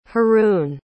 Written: haroun

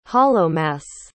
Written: hallowmas

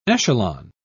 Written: echelon

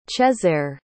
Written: chezare

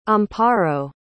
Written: amparo

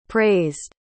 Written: praised

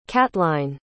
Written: catline

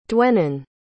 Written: Dwennon

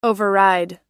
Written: override

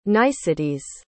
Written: niceties

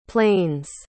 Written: planes

